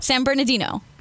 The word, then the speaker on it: San Bernardino: San Bernardino.